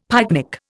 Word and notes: pyknic: Indian

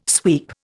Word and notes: sweep: Indian

